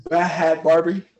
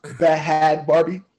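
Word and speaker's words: Bhad Bhabie: How do I say it, Marcus? – Barbie. – Bad Barbie. I know how you say it. I'm not Bad Barbie. Bad Barbie. That's how she say it.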